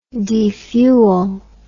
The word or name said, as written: defuel